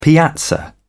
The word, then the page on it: piazza: British English